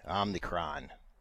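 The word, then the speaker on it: Omicron: Uh, there is a R in it. Where is it? It's after the C. Omnicron.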